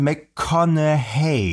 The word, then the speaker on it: McConaughey: Also es gibt, viele Leute sagen McConaughey, ja, ja. Andere sagen McConaughey, ja. Und so richtig soll es wohl sein, McConaughey.